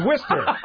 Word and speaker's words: Worcester: Like no, that's Worcester. You don't pronounce the O-R in Worcester.